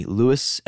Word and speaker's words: Luis: So simply email me, Luis, L-U-I-S, Luis, I guess you would say it properly, at podcastdomination, all one word, .co.